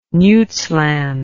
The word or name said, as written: Nuytsland